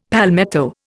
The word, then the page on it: palmetto: Indian